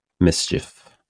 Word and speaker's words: MSCHF: MSCHF. Mischief, basically, Word. Is how you say it.